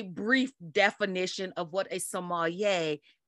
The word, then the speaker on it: sommelier: So it's actually pronounced sommelier, but you sommelier. Can, I've heard people, sommelier, okay. But I've heard people pronounce it sommelier. So okay. Either one is perfectly fine.